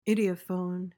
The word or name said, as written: idiophone